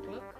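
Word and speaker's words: Glück: I think that's how you pronounce it. Gluck, with an umlaut on the U.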